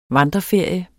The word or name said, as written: vandreferie